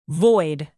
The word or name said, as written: void